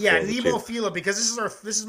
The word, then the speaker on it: Nemophila: This is Nemophila, by the way. From some of the videos, yes, some people told me how to pronounce it. It is Nemophila, which means like cute and deadly or cute and something, I think.